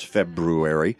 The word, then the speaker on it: February: and yes, that's how you pronounce February.